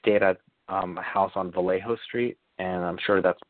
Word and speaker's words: Vallejo: It's pronounced Vallejo. The J is silent. – Vallejo.